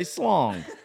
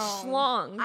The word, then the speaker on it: schlong: I was just saying next time you have to refer to that with your children, make sure you say slong. – You're saying it wrong. – I say, listen, boys. – Way wrong. – It's schlong.